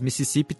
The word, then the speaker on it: Mississippi: Em português, a gente falaria que Mississippi é uma paroxítona, né? Com a sílaba forte sendo a penúltima. Mas em inglês, Mississippi tem meio que duas sílabas fortes, a primeira e a penúltima.